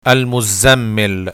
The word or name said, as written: Al-Mozamel